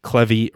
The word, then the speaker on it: Clevy: And then Matt Clevy rhymes with Chevy